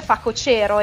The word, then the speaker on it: facocero: Non lo so, io so che si dice facocero e io dicevo facocero. E facocero, per esempio. – Però mi suona malissimo. – Lo so, sono altre due parole dove l'accento è molto importante. Pare che se non lo metti nel posto giusto, quelli proprio precisini sulla dizione potrebbero farti dei rimproveri, ecco.